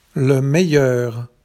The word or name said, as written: le meilleur